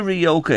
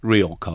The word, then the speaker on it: Rioja: We always see just Rioja on it, or Rioja as they pronounce it, you know.